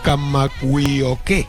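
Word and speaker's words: Kamawiyoke (Kamakawiwoʻole): Porque a ver si sois capaces de repetir conmigo Kamawiyoke.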